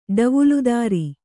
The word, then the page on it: ಡವುಲುದಾರ: kannada